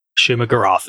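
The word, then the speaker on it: Shuma-Gorath: I think we're going to have some intertwinement with uh, Shuma Garoth. Is that how you say it? – Shuma Garoth is the only way yeah. I've ever actually heard it said.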